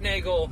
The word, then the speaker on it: Neagle: So if you could hit me back uh, on here on the chat in a callback on social, just Neagle, just dot Neagle, and that's N E A G L E or N Eagle or spelled like beagle, pronounced like bagel.